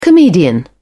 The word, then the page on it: comedian: British English